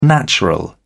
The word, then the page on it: natural: British English